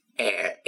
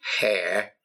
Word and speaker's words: air: Well, I'm going to look at idioms, and these idioms are connected with air, A-I-R, not hair. No, there's not much of it there, but air, A-I-R. And you've got to make sure that you pronounce that without a H. It's not hair, no, it's air, A-I-R.